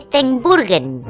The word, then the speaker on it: Steenburgen: Mira, yo te lo digo bien. Mary Steenburgen.